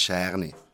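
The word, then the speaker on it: Cerniat: Also, Scherne schreibt man Gernjet mit C, Zernjet, okay, genau. Aber man sieht Scherne.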